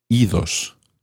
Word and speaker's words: idos: So when ir becomes reflexive, irse, meaning to go away, you actually do pronounce the D. Idos, go away. This is probably because ios is quite tricky to say. Idos sounds better.